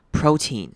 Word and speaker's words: protein: 而是念成 focus， focus，像是亲吻的那个 kiss 的声音， focus， focus on lean protein。 lean 跟 protein 这两个字都要注意，它是都是念短母长母音 e 的声音，所以会念成 lean protein， lean protein。但是 protein 注意重音还是在前面的这个音节， lean protein。